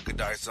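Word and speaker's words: Giudice: Goodice? – Yeah, Goodice, uh huh.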